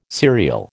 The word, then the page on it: serial: American English